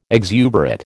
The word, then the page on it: exuberate: American English